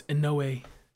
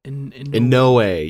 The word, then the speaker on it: Inoue: Oh, it's Inoue. – Inoue, yeah, that's how that's how you pronounce it.